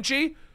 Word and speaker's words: Shang-Chi: Sh- uh, sh- how do you say it the right way? It's apparently Shang. – Shang-Chi. Uh, I did not know that.